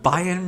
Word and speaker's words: Bayern: Is that how you say it? – Bayern Munich. – That's Bayern. – It is Bayern.